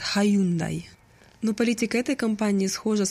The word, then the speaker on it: Hyundai: В Америке произносят Hyundai. Hyundai like Sunday.